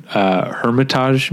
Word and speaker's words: Hermitage: And it's an unbroken shot, largely handheld or Steadicam, through the Russian Hermitage Museum.